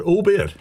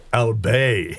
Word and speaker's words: albeit: Is it albeit? – Albeit. – In books, and thought it was pronounced French style, albay.